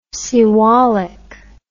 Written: Siwalik

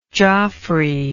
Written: Joffrey

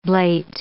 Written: blate

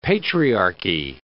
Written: patriarchy

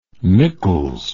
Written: nichols

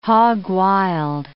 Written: hog wild

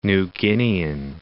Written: New Guinean

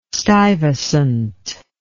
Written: stuyvesant

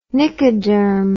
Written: Nicoderm

